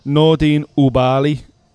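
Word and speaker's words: Nordine Oubaali: Nordin Ubali. Correct pronunciation. – Well done.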